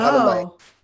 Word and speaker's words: Hahnemann: So Hahnemann, yeah.